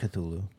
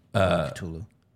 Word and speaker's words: Cthulhu: But thank you to Lord Chulhu. I still don't know how to pronounce whatever this name is. – Lord Cthulhu. – Cthulhu.